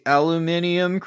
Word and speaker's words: aluminium: We should have a Christmas tree, a big aluminium Christmas tree, because that's how you pronounce that element.